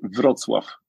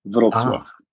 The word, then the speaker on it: Wrocław: I would say Wrocław, with a very strong V Wrocław.